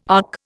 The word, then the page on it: ARC: Indian